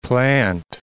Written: Plant City

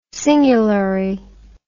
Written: singulary

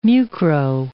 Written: mucro

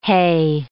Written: hae

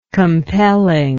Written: compellingly

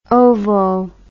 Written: ovo

